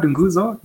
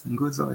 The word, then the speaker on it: Nguzo: So it's putting the N and then. Yeah, so the word Nguzo, yes, Nguzo and Saba, those are two familiar words in Swahili.